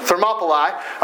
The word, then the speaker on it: Thermopylae: Thermopylae.